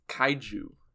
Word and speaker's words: kaiju: A non-Japanese person says kaiju.